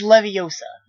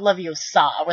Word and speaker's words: Leviosa: Besides, you're saying it wrong. It's Leviosa, not Leviosa, with, like, the capital A, like, Leviosa.